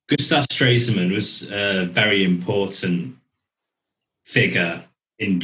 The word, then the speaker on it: Stresemann: Gustav Stresemann. – Stresemann, okay.